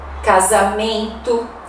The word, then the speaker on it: casamento: Você escreve com S, mas a pronúncia é de Z. Casamento, vaso, aviso, desejo, paisagem. Muitas palavras do português nós escrevemos com S, mas pronunciamos com som de Z.